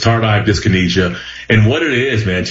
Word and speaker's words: Tardive Dyskinesia: It's Tardive Dyskinesia. Is that it? – Yeah, great job, man. It's Tardive. – Tardive. Okay, yeah, Tardive Dyskinesia.